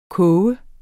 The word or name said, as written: koge